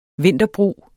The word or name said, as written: vinterbrug